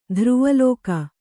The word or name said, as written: ಧ್ರುವಲೋಕ